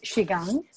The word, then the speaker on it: Qigong: You just mentioned the pronounce for me, Qigong. – It's Qigong.